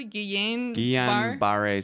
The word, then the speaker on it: Guillain-Barré: Guillain-Barré syndrome.